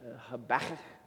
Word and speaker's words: Habakkuk: If you want to be really fun and like not COVID compliant, you can really get the guttural sound of it, Habakkuk, and kind of like get some spit to come out when you say it, which is the good Hebrew way to pronounce that name, deep in the throat.